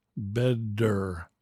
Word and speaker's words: better: The word better is spelled B-E-T-T-E-R. But the T-T is not pronounced better. It's pronounced like a D sound, the sound of dog. Better, bedder, better.